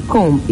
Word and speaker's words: comb: And this simply means if you add ING to comb, it will still be combing. Comb, combing.